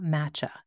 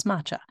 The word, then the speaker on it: matcha: Did I say that right? I always say it wrong. It's not matcha. Yeah, it's matcha. M-A-T-C-H-A.